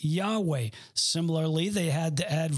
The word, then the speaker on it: Yahweh: And with the added vowels, we pronounce it Jehovah.